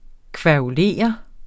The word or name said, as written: kværulere